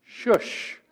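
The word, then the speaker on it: shush (شوش): And the location of the capital, Susa, which actually in Persian is pronounced shush.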